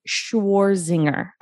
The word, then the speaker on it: Shwarzinger: Did I pronounce that correctly? – It's very close. Very good. – All right. Can you remind me how to pronounce it so I can make sure I say it correctly? – Schwarzinger.